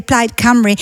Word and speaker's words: Plaid Cymru: Certo, e come correttamente ricordavi, correttamente pronunciavi, la vittoria di Plaid Cymru,